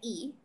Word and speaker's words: Lee: Claro, y aquí uno, con lo que te había contado en la pauta, que Lee, que en realidad se pronuncia I,